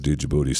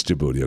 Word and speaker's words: Djibouti: Because it's got the big D in front of it, and it should be pronounced Djibouti. Stupid that it's pronounced Djibouti without the D. That the D is silent.